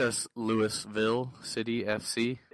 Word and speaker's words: Louisville: You're saying it wrong. – Sorry, Louisville. – Louisville. – Louisville. – They will correct you.